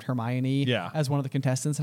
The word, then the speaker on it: Hermione: I'll also point out, on this exact same note, that this is the chapter where we meet Hermione, we also called Hermione Hermoine for I don't know. – Until the movies. – Yeah. – We had no idea how to pronounce it.